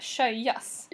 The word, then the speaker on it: sjöjas: Ja, du måste berätta för alla vad du lyssnar vad sjöjs betyder. För jag tror inte att alla vet vad det betyder. – Okej, du uttalas först och främst sjöjas.